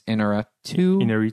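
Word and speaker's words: Alejandro González Iñárritu: Alejandro González Iñárritu.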